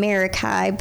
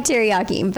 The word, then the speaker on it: Meraki: So I discovered Kara through her swimwear company, which is called Meraki Swim, which I was pronouncing Marikai, but it sounds more like teriyaki is how I remember it. So That's a good little mental note. – Right. So kind of like teriyaki, but okay.